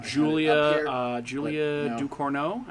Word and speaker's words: Julia Ducournau: Julia Ducournau, I believe. – Yes, I believe that's correct pronunciation.